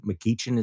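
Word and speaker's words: McGeachin: The governor of Idaho, Brad Little, beat the insane Trump-endorsed lieutenant governor, Janice McEachin, is that how you pronounced it? Yeah.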